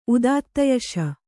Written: ಉದಾತ್ತಯಶ

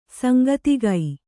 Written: ಸಾಂಕೇತಿಕ

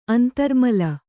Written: ಅಂತರ್ಮಲ

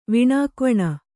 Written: ವಿಣಾಕ್ವಣ

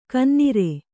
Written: ಗೊನ್ನೂರು

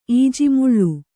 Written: ಈಜಿಮುಳ್ಳು

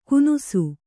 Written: ಕುನುಸು

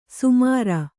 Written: ಸಮರು

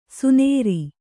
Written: ಸೊನರು

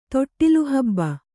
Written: ತೊಟ್ಟಿಲುಹಬ್ಬ

